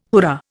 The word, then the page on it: hurrah: Indian